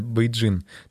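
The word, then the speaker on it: Beijing: почему на русском языке столица звучит как Пекин, а на самом деле, ну, либо там большей частью Западной Европы и Америкой, называется их столица Бейджин.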